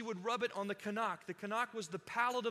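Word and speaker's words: kanak (חנך): It's the word kanak. Can everyone say that with me? Can you pull that up on the slide? Here's how you say it. Can you say that? Kanak.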